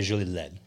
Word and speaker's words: Jolie-Laide: If you were saying it correctly, you say Jolie-Laide, almost like Led Zeppelin.